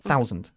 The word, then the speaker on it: thousand: Mejor es escuchar cómo se pronuncia que leer cómo se escribe, porque no es muy fonético como vocablo, ¿ok? Thousand, dilo bien. – Thousand. – Thousand, eso es, ¿ok?